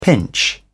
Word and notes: pinch: British English

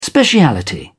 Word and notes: speciality: British English

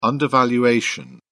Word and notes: undervaluation: British English

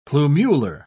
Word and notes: plumular: British English

